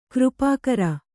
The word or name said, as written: ಕೃಪಾಕರ